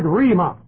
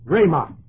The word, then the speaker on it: rhema: So it's not the word of God, the Bible, and it's not Jesus, it's the Greek word rhema. Rhema, R-H-E-M-A, and it's long E, A, pronounces A. Rhema.